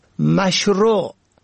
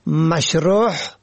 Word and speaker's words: مشروع: به گونه مثال مشروع که معنای آن آنچه مطابق شرع است را شماری مشروع یا مشروح به هی حلقی تلفظ می کنن که شرح شده معنا می دهد.